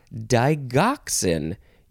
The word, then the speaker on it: digoxin: It is pronounced either digoxin or digoxin. You can pronounce the G either way. D-I-G-O-X-I-N.